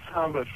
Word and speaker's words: Samer: It looks like Samer or Samir. How do I say this? – Yeah, Samer.